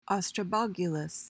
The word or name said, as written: ostrobogulous